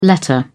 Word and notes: letter: British English

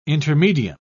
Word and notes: intermedium: British English

